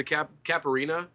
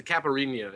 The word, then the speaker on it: Caipirinha: My drink of choice is the Caipirinha. I mispronounced it. It's the Caipirinha, if you speak Ooh.